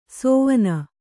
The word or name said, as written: ಸೋವನ